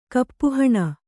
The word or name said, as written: ಕಪ್ಪೆಹುಣ್ಣು